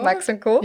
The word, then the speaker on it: Max & Co.: Habe ich es richtig ausgesprochen? – Ja, Max & Co. – Max & Co.